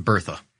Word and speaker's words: Bertha: Yep. I pronounce it Bertha. – Ah, yes. Yeah. – That's all right. You could say Berta if you want. I've heard it pronounced that way too, but I'm going to say Bertha. You'll say Berta, whatever.